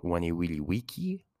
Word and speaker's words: Veni Vidi Vici: Veen comes from oneiowentum, which means to come, just like in uh, Caesar's great quote, Veni Vidi Vici. Remember, these are pronounced as W's, as I will always reiterate, just so that you guys don't forget.